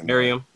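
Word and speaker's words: Meruem: But So basically is it Miriam, how you pronounce his name? – It's Miriam. – All right, yeah. Miriam.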